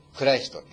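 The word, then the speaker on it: Christ: 英語でクライストってちょっと違った発音だけど、似たような発音で言いますけども、そこにも、クリスチャン、クライストってことが入ってるわけですね。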